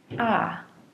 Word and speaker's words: cock: The word C O C K is pronounced with a short O sound ah. I'm going to change the beginning K sound to a P sound and use the word pak.